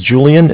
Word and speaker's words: Julian: All right, my name's Julian. It's J-U-L-I-A-N. – Uh-huh. – I'm calling with Bank of Cardiff. Cardiff is spelled C-A-R, D as in David, I-F-F as in Frank Frank.